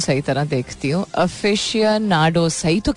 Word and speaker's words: aficionados: लिखते अफिशियोनाडोज हैं, लेकिन ये कहते अफिशियोनाडोज नहीं, अफिशियांडोज या ऐसी कुछ प्रोनाउंसिएशन होती है इसकी. इट्स विद एन AFICIONADOS.